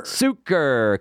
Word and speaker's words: Sucher: Suker.